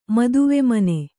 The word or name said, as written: ಮದುವೆಮನೆ